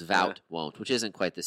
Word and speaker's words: Wout: How do you pronounce his first name? – Vout. – Vout. Okay, so it starts with a W.